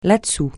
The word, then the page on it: là-dessous: francais